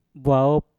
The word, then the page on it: bwaop: Mpur